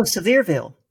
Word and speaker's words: Sevierville: Been, and I'm not going to say it right. Sevierville. It's by Gatlinburg. – Oh, Sevierville. – Well, there you go.